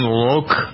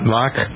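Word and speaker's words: Lōc: Did you know this guy's name was pronounced Tone Loke? – Loke. – Not Lock? Loke?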